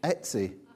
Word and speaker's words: Etsy: This is on, I think there's a website called Estee, is it Estee? Etsy. I can never pronounce it. It's where you buy these cool things that you can't buy on the high street. What's it called again? Etsy.